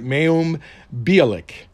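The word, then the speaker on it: Mayim Bialik: So will it be I'm sorry, let's get her name right, Mayum Bialik, or LeVar Burton. Who's it gonna be? You know, I like both of them. I honestly do. I like Mayum Bialik because of, you know, Big Bang Theory, and because of the fact that she's a freaking genius.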